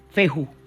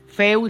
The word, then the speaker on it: fehu: Se escribe F-E-H-U, feju. Feu, le dicen feu, fe, feju, como tú quieras decir.